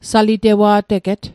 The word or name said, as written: salitewa'teget